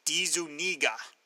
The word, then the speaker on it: DeZuniga: My friend and fellow historian Jason Sachs, Tomorrow's American Comic Book Chronicles, corrected my mispronunciation. Hey, it's a tough one, but he told me it's DeZuniga.